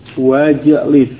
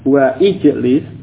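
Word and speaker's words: Wajlis (واجلس): Kemudian pada ijlis dia di tengah kalimat ada kata lain yang mendahuluinya, maka hamzah wasal yang di awal fil amr tersebut tidak diucapkan. Wajlis. Ya, bukan wa ijlis tapi wajlis. Ya, hamzahnya wasalnya tidak diucapkan.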